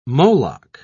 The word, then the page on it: moloch: British English